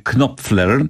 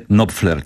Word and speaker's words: Knopfler: Otóż, panie Markusie, Węgier powie Knopfler, Niemiec powie Knopfler, a Anglik powie Knopfler, czyli wszystko się zgadza i wszystko jest bardzo poprawnie.